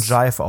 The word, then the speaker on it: GIF: Jive, das spricht man Jive aus.